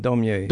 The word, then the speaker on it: Daumier: Daumier.